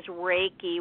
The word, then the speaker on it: Reiki: All right, and that, and the central word there is Reiki, which is R-E-I-K-I, Reiki.